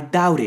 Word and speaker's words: doubt: I doubt it. I doubt it. Recuerda que en este caso la B no se pronuncia.